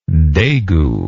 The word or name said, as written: degu